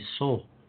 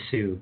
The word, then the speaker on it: Sault: Marie, Canada, pronounced in the crazy French manner after its a spelling. Though, having taken French, it feels like if it's S-A-U-L-T, that would be Sol, as opposed to Sue.